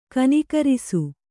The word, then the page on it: ಕನಕರಿಸು: kanakarisu